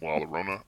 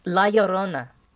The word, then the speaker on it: La Llorona: So the first one I had is La Llorona. I thought that movie was just like... – It's La Llorona.